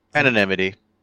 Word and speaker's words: anonymity: Just so he can achieve some sort of anonymity, a hip-hopopotamus. – Anonymity. – Anonymity. I was putting the emphasis on the wrong syllable.